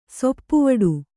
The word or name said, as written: ಸೊಪ್ಪುವಡು